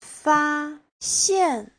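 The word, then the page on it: faxian: fa1xian4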